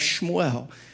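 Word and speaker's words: Shmuel (שמואל): If you say it properly, it should sound like Shmuel.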